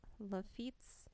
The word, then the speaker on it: Lafitte: So, this is the site where Jean Lateef's town and headquarters once stood. – Okay. – And where the Battle of Three Trees was fought between Lafitte's.